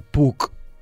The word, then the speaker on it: Puck: Puc, escrit P-U-C-K, es pronunciaria semblant a PAC, això uh -huh.